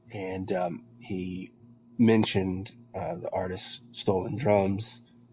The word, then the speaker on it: STLNDRMS: Then we have track four here from an artist by the name of, I believe it's pronounced Stolen Drums or S-T-L-N-D-R-M-S, but I believe that basically says Stolen Drums.